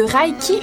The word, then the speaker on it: Raki: Donc, je vous parle du Raiki. Là, ça s'écrit, en fait, Raki, R-A-K-I. Mais c'était écrit que ça se dit E-U à la fin. Puis il faut faire un rictus comme en disant le E, fait que c'est Raké.